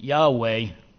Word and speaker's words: Yahweh: And so we filled in the Hebrew language not supplying the vowels, we fill it in and pronounce it Yahweh, which comes from the Hebrew word Haya, which means to be.